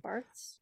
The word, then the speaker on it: Barthes: Barthes.